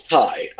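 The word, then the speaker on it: Ti: And it was directed by Ty West, or T West, if that's how you're pronouncing it, because it's spelled T I. – I feel like it's Ty.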